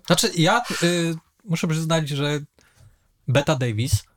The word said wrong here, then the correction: Bette Davis: Bette Davis. – No teraz tak, ale powiedziałeś Betta Davis.